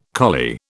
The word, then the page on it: collie: British English